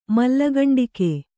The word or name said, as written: ಮಲ್ಲಗಂಡಿಕೆ